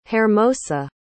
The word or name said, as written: Hermosa